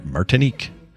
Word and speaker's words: Martinique: Martinique, I think is how you say it. – Yeah, you said it perfectly.